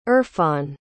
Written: irfan

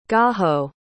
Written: Gaho

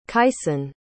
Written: kyson

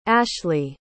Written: ashley

